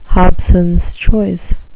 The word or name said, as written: hobsons choice